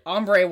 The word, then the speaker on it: Ambre: And Amber, which is spelled A-M-B-R-E. – Ombre won, which made no sense at all.